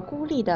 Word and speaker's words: 孤立: 这个孤立的孤是一声，而鼓励的鼓是三声。你把孤立和鼓励这两个词的声调搞混了。